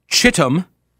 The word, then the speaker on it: Chittum: I'm going to pronounce that C-H as a hard C-H, as a ch sound. Not like a Chicago, kind of a soft shh. Not going to do that. The name is Chittum.